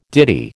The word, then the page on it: ditty: American English